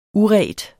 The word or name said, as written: uredt